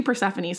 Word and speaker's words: Persephone: Very confidently said her Greek god was Persephone, which, if you know how to pronounce, like we said, Emily's having problems pronouncing words and saying words. It's actually Persephone.